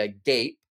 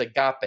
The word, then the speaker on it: agape: and then you say agape, right? No. It's agape.